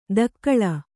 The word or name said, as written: ಧಕ್ಕಳ